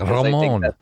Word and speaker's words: Ramon: Ramon. You got to roll your R when you say that.